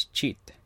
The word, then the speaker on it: chit: I don't know if it's like a slang word or just a mispronounced word, but check in Tagalog is cheat. Like C-H-I-T, cheat, cheat, cheat.